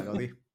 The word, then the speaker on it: Gaudí: No, es eh, Gaudí, Gaudí, sí, aguda, graves de jula. Yo siempre tengo que hacer aguda, graves de jula, ese vocal, así. – No, sí, es aguda. – Está bien. – La última sílaba, Gaudí.